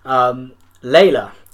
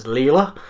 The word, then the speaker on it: Leyla: Layla, 18 years old. I don't know, that is pronounced Layla, although it's spelled L-E-Y-L-A, so I want to pronounce it as Leela. But I'm going to just say Layla.